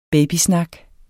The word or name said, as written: babysnak